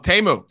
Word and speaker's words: Temu: Oh, Temu.